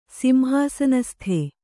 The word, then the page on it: ಸಿಂಹಾಸನಸ್ಥ: Kannada